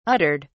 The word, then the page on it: uttered: British English